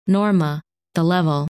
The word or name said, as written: Norma